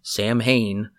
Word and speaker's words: Samhain: If you go back and rewatch Halloween 2, you will notice that Michael wrote Samhain, which is not how it's pronounced. It's Samhain, I believe.